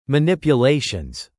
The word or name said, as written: manipulations